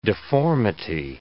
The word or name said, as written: deformity